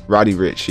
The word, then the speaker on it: Roddy Ricch: roddy whip um, anyways, Roddy Ricch. Y'all didn't hear that last uh, pronunciation.